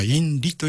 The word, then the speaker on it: INRI: でそれの 4 文字の頭文字を取って INRI と書くと、まあ、インリというふうに発音したりするんですけども、うん、 INRI、これが例えば十字架の上のところに頭文字で INRI というふうに書かれていた場合には、ナザレのイエス、ユダヤの王と宣称した罪によって、裁かれるということを意味するんですね。